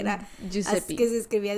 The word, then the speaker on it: Giuseppe: Jisupi, sí. ¿No es Giuseppe? Mm, o Giuseppe.